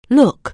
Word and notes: look: anglais

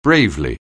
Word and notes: bravely: anglais

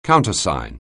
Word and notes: countersign: anglais

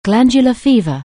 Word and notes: glandular fever: anglais

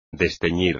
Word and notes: desteñir: espagnol

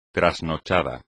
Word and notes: trasnochado: espagnol

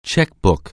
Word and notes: checkbook: anglais

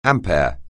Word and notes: ampere: anglais

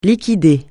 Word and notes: liquider: francais

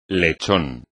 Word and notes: lechón: espagnol

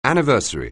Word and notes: anniversary: anglais

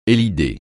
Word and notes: élider: francais

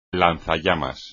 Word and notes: lanzallamas: espagnol

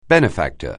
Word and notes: benefactor: anglais

